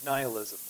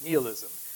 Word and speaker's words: nihilism: Next one is nihilism, or sometimes pronounced nihilism.